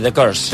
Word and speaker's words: The Curse: A veure, el primer que ens portes és The Curse, no? Es pronuncia així? – Sí, The Curse.